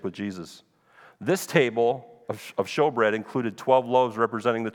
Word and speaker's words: showbread: It says shewbread. That's an old English way of saying it, but it's show. It's pronounced showbread.